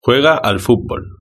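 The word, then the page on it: juega al futbol: Spain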